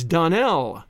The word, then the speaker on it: Donnell: "Clarice Almira told me that you pronounced our name Donnell." "Now, Miss Shirley, the correct pronunciation of our name is Donnell. Accent on the last syllable.